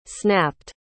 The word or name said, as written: snapped